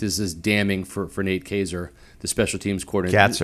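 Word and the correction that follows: Kaczor: Katzer. It's spelled really crazy, but yeah, looks like Kazor, but we'll call him Katzer.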